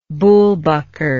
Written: bullbucker

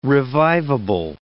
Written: revivable

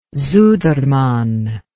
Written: sudermann

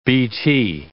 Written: BT

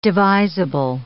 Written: devisable

